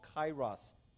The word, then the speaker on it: kairos: but it's a word called kairos time. You can spell it K-A-I-R-O-S. It's a Greek word.